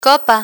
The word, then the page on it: copa: Spanish